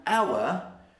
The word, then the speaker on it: hour: Whereas an hour, the H is silent. We don't pronounce the H. So we say an hour, because even though we write it with an H, we don't say the H.